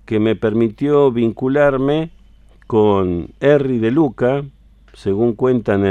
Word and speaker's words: Erri: Pero como para sus amiguitos Tano, Harry era raro de pronunciar, quedó Erri, porque le decían Erri, así que ahí tiene ese extraño nombre.